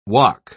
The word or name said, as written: wok